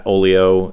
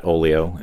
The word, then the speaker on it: Oglio: We have Twitter, which is at Oglio, and Instagram, which is also at Oglio, and it's spelled O-G-L-I-O.